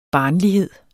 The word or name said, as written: barnlighed